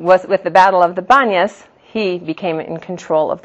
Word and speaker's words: Banias: but in Hebrew today we call it the Banias, which is really from the Arabic pronunciation of Panias.